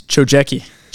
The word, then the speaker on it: Chojecki: It's Chojecki. – Chojecki, because he's Polish, guys.